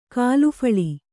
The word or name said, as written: ಖಳಫಳ